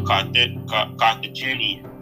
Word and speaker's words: Carthaginian: He was a Carthaginian. I can't say it, you know. I'm trying to talk. – You said it right the first time. – That's what I'm doing. Carthaginian general.